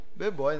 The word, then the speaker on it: B-boy: E hoje estamos aqui com B-Boy, né? – É B-Boy? Como é que se pronuncia? É, B-boy. B-Boy?